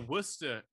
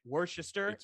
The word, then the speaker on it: Worcester: Yeah, but the Worcester in Ohio is actually spelled the way Worcester is pronounced.